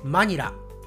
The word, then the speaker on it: マニラ: はい、日本人もね、同じ言い方をしますね。マニラ。